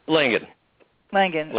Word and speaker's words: Langan: Langan. – And L-A-N-G-A-N, yep.